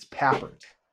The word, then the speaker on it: Papert: No, and it took me like 2 years to figure out, okay, find somebody to pronounce it for me. It's Papert. – Papert, okay. – Yeah, yeah. – So I just kind of think of paper and then Papert.